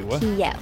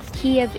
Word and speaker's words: Kyiv: And it's not Kiev. Kyiv is Russian spelling and pronunciation. Kyiv. – Kyiv. – That's how you say it in Ukrainian.